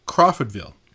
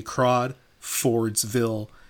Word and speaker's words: Crawfordville: Crawfordville. I was having extreme difficulty pronouncing this town because I wanted to say Crawfordsville as like singular like names in the town.